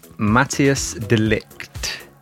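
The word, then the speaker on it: Matthijs de Ligt: Matthias De Ligt.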